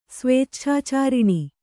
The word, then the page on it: ಸ್ವೇಚ್ಛಾಚಾರಿಣಿ: svēcchācāriṇi